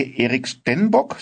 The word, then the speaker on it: Erik Stenbock: Der Autor war in diesem Fall auch selbst homosexuell und er heißt, wenn ich ihn richtig ausspreche, Erik Stenbock. Sagt man so zu ihm? – Du, ich würde das genauso aussprechen. Er ist in Schweden geboren und ein adliger Junge, der auch nicht besonders alt geworden ist.